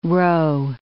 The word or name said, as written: roe